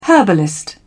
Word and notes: herbalist: British English

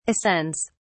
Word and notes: ascends: British English

